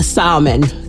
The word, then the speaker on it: salmon: A salmon. So you don't no L.